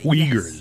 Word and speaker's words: Uyghurs: The Uyghurs. I found out it doesn't look like that's how you say it, but that's how you pronounce it.